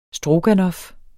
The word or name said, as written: stroganoff